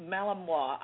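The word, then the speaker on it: Malinois: They're Belgium Malamutes. Am I pronouncing it? – Malamois. I botched the name, too. I constantly call them Malamar, which is a cookie. they're Belgian Malamois.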